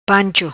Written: Pancho

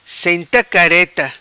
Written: Senta Careta

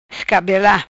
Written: Escabela

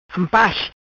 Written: Ampas

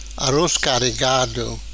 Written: Aroz Carregado

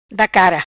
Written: Da cara

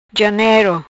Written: Janero

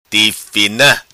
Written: Tifina

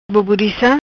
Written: Boborica

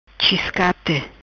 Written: Chiscate

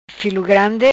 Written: Filo Grandi

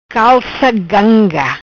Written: Calca Ganga

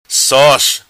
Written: Sos

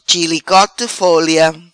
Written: Chilicote folia

